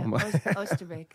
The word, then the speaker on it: Oosterbeek: We're going to Oosterbeek. Is that how you pronounce it? – Almost, yeah. Oosterbeek.